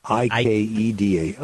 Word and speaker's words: Ikeda: It's IKEDA. It's not pronounced like the furniture store. – IKEDA.